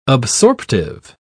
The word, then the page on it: absorptive: British English